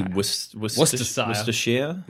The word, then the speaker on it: Worcestershire: Would that be Worcestershire? – Like the sauce.